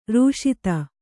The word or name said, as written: ರಶೀತಿ